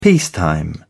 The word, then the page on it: peacetime: British English